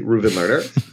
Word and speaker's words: Reuven Lerner: And I'll at least try to pronounce my name correctly, Reuven Lerner.